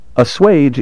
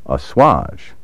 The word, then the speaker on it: assuage: Assuage is sometimes mispronounced assuage or assuage. These recent variants have made their way into a few current dictionaries, but the traditional and proper pronunciation, countenanced by all dictionaries, is assuage. Word 38. Corroborate. C-O-R-R-O-B-O-R-A-T-E.